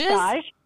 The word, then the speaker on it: stage: Stage? – Stage. It's spelled like stage, but it's French.